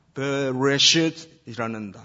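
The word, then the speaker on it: 베레시트라는 (בְּרֵאשִׁית): 제일 처음에 등장하는 이 단어, 발음이 별로 안 좋지만은 히브리어로 읽으면은 베레시트라는 단어예요. 베레시트, in the beginning, 태초에.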